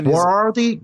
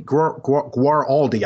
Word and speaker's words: Guaraldi: What's the name again? – Guaraldi, Guaraldi. I think it's Guaraldi. I think that's how you pronounce it.